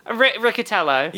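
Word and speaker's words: Riccitiello: It was about, how do you pronounce John Ricciello? – Ricciatello.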